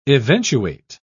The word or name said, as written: eventuate